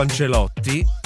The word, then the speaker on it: Ancelotti: Tant'è che il mister del Brasile della nazionale, Carlo Ancelotti, che loro chiamano Ancelocci.